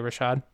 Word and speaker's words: Rashad: Do you know if I'm saying that right? Uh, Rashad? – Thanks, Rashad. Isaiah Rashad? – Mm-hmm.